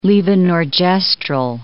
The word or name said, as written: levonorgestrel